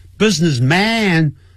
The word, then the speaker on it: businessman: Sam is a successful, businessman 啊，那 business 啊，那个 man 呢就念成 man， businessman 啊，不需要这样念，不需要发的那么强啊， businessman， he's a successful businessman，他是一个很成功的生意人。